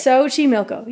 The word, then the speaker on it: Xochimilco: Xochimilco.